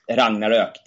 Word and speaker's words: Ragnarök: Yes, Ragnarök, that is how you pronounce it in Swedish.